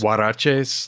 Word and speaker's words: huaraches: Horaches. Or I don't know if you pronounce the H. Horaches? Or it's huaraches.